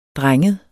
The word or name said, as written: drenget